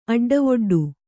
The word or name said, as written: ಅಡ್ಡವೊಡ್ಡು